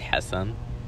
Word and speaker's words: Hassan: I mean, so my name is Hassan. – Hassan, right. – So in my native language, it's pronounced Hassan. – Mm. – But people can't pronounce it here.